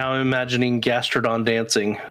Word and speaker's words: Gastrodon: Gastrodon.